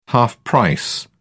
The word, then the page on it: half-price: British English